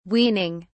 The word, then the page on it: weaning: British English